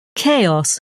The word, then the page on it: chaos: British English